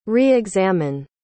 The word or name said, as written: re-examine